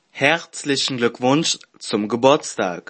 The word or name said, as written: herzlichen glueckwunsch zum geburstag